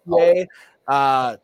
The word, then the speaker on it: Poitier: Is that Poitier? – Poitier.